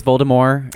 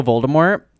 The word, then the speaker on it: Voldemort: Like the way they pronounce Voldemort instead of Voldemort. – Yes, that is my only critique of the show, is that they consistently pronounce it Voldemort. And I know that J.K. Rowling says that is right, but she is wrong in this instance.